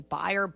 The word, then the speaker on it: Bayer: It's a German company. They're soaring. We pronounce it Bayer here.